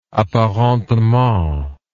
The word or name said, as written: apparentement